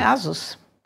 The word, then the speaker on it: Asus: E aí eles disseram uma coisa que nunca mais saiu da minha cabeça, rima com Pegasus. Pegasus. – Ah, entendi. Entendi. – É Asus.